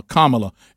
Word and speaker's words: Kamala: You just need to know it's like a comma, Kamala, and you need to say it right out of respect.